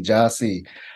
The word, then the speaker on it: Jahsee: You know, it took me a while to get to really fully embrace it, but like people say it wrong all the time when they see it, even though it's phonetic, Ja, J-A-H-S-E-E, Jassy.